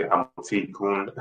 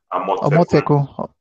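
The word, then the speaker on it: Amotekun: So I guess some people who are watching from the US, UK, other parts will probably say Amote Kun is the way they'll probably pronounce it. but we're pronouncing it in the Yoruba context. It's a Yoruba word, Omoteko.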